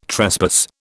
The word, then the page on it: trespass: British English